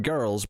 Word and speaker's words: Grrrls: Well, it's Lizzo's Watch Out for the Big, and then it's maybe the word girls, but it's spelled G-R-R-R-L-S. – Right, okay. – Hence the emphasis on the prolonged R.